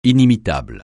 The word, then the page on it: inimitable: francais